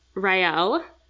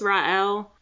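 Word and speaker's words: Raël: So if you're going to picture it, it's R A E with an umlaut L. So I don't know if that changes the way you pronounce the E. What I researched was that it just makes it like two separate syllables. So I think it's Ra'el.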